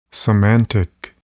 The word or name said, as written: semantic